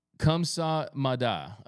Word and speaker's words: kamsahamnida: Thank you in Korea is pronounced kamsahamnida.